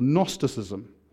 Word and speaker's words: Gnosticism: And the first of these was a thing called Gnosticism, which is spelt with a gnu, like I'm a gnu.